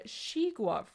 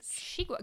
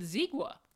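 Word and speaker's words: xigua: No, this is a shigua fruit. – Oh, is that how you pronounce it? – It is. – Shigua, because I would have said zigua.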